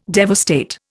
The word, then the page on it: devastate: Indian